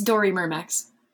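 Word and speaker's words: Dorymyrmex: And is that right? That I pronounced any of that right? – It's Doramimex.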